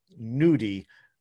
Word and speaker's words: gnudi: Like apart, well, the Trinidadian double is pretty special, but the fufu nudi with mafe sauce, and mafe is spelled MAAFE, mafe sauce, and nudi is spelled GNUDI. It's like the cousin of gnocchi, and fufu is a something I'd never heard of, and it's basically like cassava stuff.